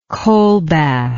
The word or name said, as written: colbath